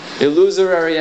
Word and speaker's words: illusory: The word you want is illusory. Okay? It's embarrassing to hear illusory. – Illusory and the other false pronunciation. – Illusory. Illusory. Illusory. It's a four-syllable word. No more.